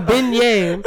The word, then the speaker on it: beignet: A beignet. And the A is a long sounding A. Had to explain that before I had to give all you a nigga fuck you for pronouncing it wrong.